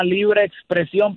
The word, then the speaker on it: Libre Xpresion: importante aclarar para los que nos están escuchando y no nos conocen que el grupo se llama Libre Expresión, pero se escribe Libre X Expresión.